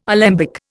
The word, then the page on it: alembic: Indian